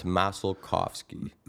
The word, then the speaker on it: Masalkofski: It's Masalkofski.